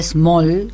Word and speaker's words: Smol: una muestra en la Galería Small, que es de Santiago Bengolea, en La Boca. Small es como chico, pero se escribe como español, S-M-O-L.